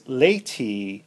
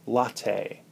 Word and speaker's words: lati: The TFL is also referred to as the coffee muscle because lati is often mispronounced as latte.